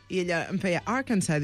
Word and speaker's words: Arkansas: Ell és d'Arkansas, però és que no vull dir Arkansas així, perquè no es pronuncia així. – Ah, no? – Arkansas. – Jo he dit sempre, tota la vida he dit Arkansas.